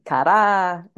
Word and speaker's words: Kara: Cara.